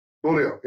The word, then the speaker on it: Beaulieu: Did I say your name right? The last name? – Gator Beaulieu. – Beaulieu, yeah.